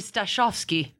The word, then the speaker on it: Stasiowski: In Polish, it would be Stasiowski.